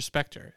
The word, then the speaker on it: Spectre: Naomi Harris had to shoot her entire role in 3 days in between her promotional tour for Spectre. That's how you pronounce it, right? – Yes. – Yeah. It's like Spectrum.